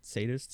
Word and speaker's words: sadist: Is it pronounced sadist? – Sadism. – Sadism? – Yeah. – A? – A? – Wait, with an A? Sad? That's not sad. – Sadist. – Sadist. – Yeah. – Why? There's no E, though. – The I is the E. – What do you mean the I is it's pronounced that way? – English is a stupid language.